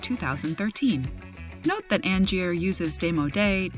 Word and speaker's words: démodé: The word of the day is démodé, spelled D-E-M-O-D-E, but note that both E's are spelled with an acute accent.